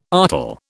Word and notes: artel: British English

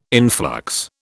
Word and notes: influx: British English

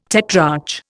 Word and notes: tetrarch: Indian

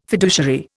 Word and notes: fiduciary: Indian

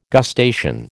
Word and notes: gustation: American English

